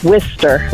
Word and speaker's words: Worcester: It's spelled Worcester, right. – And it's pronounced Worcester. – Worcester. – Like Wister.